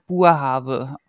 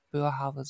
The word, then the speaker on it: Boerhaave: So, und bei der Niederländer spricht man es Boerhaave aus und nicht Boerhaave, so wie wir das immer falsch machen irgendwie.